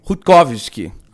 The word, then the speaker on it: Rutkowski: pensando aqui em cima desse caso do Greg Rutkowski, não sei como é que fala. Fala aí. – Foi. Greg Rutkowski.